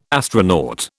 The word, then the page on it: astronaut: British English